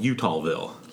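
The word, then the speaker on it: Eutawville: It was called Utahville. – Utahville? – Yes, but it's not spelled like the state. It's spelled E U T A W.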